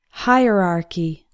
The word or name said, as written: hierarchy